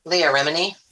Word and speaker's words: Leah Remini: Leah Remini.